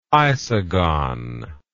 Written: isogon

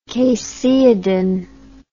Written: caseidin

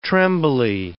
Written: trembly

